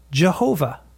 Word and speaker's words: Jehovah: So you get, you guessed it, Y-E-H-O-W-A-H, which is pronounced Jehovah.